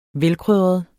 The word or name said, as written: velkrydret